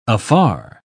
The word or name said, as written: afar